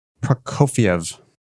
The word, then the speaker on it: Prokofiev: Let's go Prokofiev.